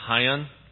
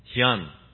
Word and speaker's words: Hyun: My name is George Hyun, and Dave got it right. Originally, my parents are from Korea, and so our last name is actually pronounced Hyun, like the car, Hyundai, right?